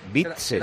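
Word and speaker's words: Witsel: Es Witzel.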